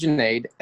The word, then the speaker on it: Juneid: My name is Juneid,